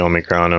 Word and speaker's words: Omicron: obviously Omicron and all that stuff. I think that's how you pronounce it. – Yeah, that sounds right. I mean, right to me.